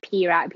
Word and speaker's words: Pyra: But then it's P-E-R-A, right? And then Thai people just pronounce it like Pyra, Pyra.